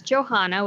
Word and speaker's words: Johanna: My name is Johanna with an H in the middle.